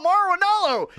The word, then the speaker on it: Mauro Ranallo: Mauro Ranallo? – Oh, Mauro Ranallo!